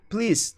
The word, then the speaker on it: please: Ó, a pronúncia não é please, tá, gente? É please. Please. É um som de Z no final.